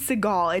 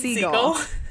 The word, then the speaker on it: Segel: Jason Segel rom-com together. – It's not Jason Segel, it's Jason Segel. Yeah. It's not like Steven Seagal's brother, Jason.